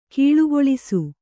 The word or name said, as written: ಘುಳುಘುಳಿಸು